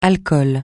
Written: alcool

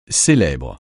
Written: célèbre

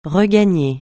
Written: regagner

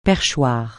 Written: perchoir

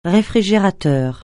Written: réfrigérateur

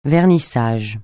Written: vernissage

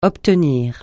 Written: obtenir

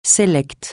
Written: sélect

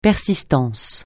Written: persistance

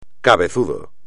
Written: cabezudo

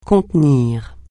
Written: contenir

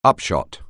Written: upshot

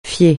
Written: fier

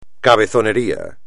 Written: cabezonería

